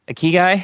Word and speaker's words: ikigai: Is it ikigai? – Ikigai? – Ikigai.